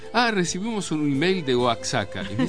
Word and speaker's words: Oaxaca: Y agrega César en su email: otra cosa, Oaxaca. La X se pronuncia como J. Sí,